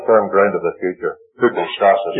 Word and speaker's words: hypostasis: The man who has faith is hypostasis.